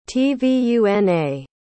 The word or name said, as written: tvuna